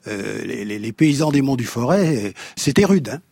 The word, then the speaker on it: Forez: Forez, enfin non, pardon, le Forêt, sinon je vais me faire, le Forêt, on ne prononce pas le Z final, le Mimi, bouilli de farine au lait.